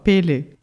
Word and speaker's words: Pele: Mas fui buscar e caiu um vídeo engraçadíssimo que os caras alternavam a pronúncia da deusa havaiana com o do Pelé. E eles falavam exatamente igual, Pele.